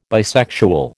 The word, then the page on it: bisexual: American English